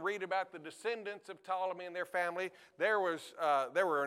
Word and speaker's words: Ptolemy: Ptolemy, the P is silent. Ptolemy,